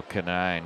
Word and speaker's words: Conine: Brett Kanine, C-O-N-I-N-E. It's spelled like the, Mr. Marlin, Jeff Conine. But Brett is not related, and his family pronounces the name differently.